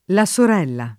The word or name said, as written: La Sorella